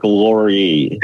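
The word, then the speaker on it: Lore: Listen, it's pronounced Mark Lurie. – It's Lurie. Thank you.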